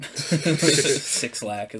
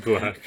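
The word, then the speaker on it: 6LACK: Six Lack is what I'm doing. – So. It's just Black.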